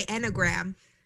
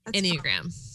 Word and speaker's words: Enneagram: We are both is that how you say it? Enneagram? I always say Enneagram. – Enneagram. – I just leave out the other E.